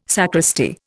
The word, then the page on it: sacristy: Indian